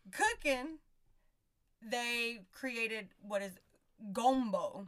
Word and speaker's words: gombo: And gumbo actually comes from the word, I guess you would pronounce it gombo. It's spelled G-O-M-B-O.